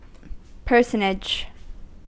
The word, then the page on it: personage: American English